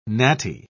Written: natty

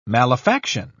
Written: malefaction

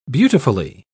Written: beautifully

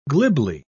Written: glibly

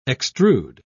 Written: extrude

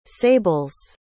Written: sables

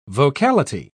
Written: vocality

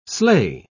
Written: sleigh

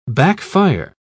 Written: backfire

